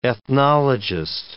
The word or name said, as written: ethnologist